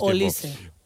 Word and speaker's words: Olise: Podrías preguntarlo, pero yo ya en la Champions lo hicimos y pues era Olise.